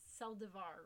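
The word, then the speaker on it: Saldívar: I always say that wrong. It's Saldivar, right? – You know, it's Saldivar.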